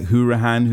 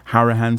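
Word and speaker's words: Hourihane: I think we've seen the likes of Andre Green, we've seen the likes of Samatta, Engels, Gilbert, Hourihan, who you've mentioned, Hourihan, sorry, to pronounce it correctly.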